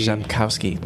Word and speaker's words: Rzepkowski: Rzepkowski. No, yeah. R. – The R is silent. – The R is silent. – I always tell people the R is silent, like the P in swimming.